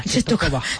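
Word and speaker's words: Częstochowa: Antonio, Cestochoa, o cómo se dice. – Cestocoba.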